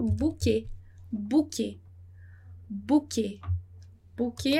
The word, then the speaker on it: bouquet: Muito bem, então como eu já falei, né, o S no final de palavra a gente não vai pronunciar e nem o T. Então a gente vai falar bouquet. Bouquet, como em português.